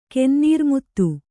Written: ಕೆನ್ನೀರ್ಮುತ್ತು

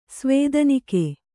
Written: ಸ್ವೇದನಿಕೆ

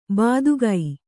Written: ಬೆದಕು

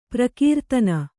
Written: ಪ್ರಕೀರ್ತನ

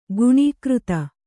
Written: ಗುಣಾಕೃತಿ

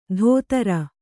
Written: ಧೋತ್ರ

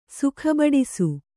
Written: ಸುಖಬಡಿಸು

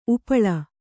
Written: ಉಪ್ಪಲ

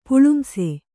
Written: ಪುಳುಂಸೆ

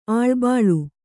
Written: ಅಳಿಬಾಳು

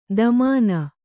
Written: ದಮಾನ

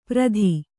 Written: ಪ್ರತಿ

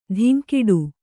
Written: ಧಿಂಕಿಡು

